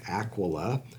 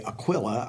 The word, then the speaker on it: Aquila: She was an evangelist and teacher with her husband Aquila. A lot of times people say Aquila, I guess because it rhymes with Priscilla, but what's interesting is her name is listed first in the text, which is very unusual for the time.